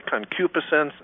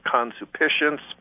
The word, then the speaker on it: concupiscence: It's pronounced two different ways. Some people say concupiscence. Other people call it consupiscence.